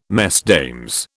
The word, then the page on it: Mesdames: British English